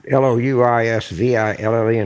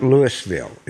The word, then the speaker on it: Louisville: Yeah, okay, so I'm not sure for the Louisville and Colorado, but when you talk about Louisville in Kentucky, it's not Louisville, it's Louisville.